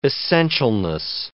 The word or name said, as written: essentialness